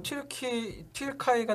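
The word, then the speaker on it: Türkei: 일단 뭐 튀르카이 같은 경우는 튀르키예 같은 경우는 아 독일어로 발음했는데 튀르카이는 독일어인데 아 독일은 튀르카이라고 해요. 예, 옛날부터? – 예, 카이라고 하거든요.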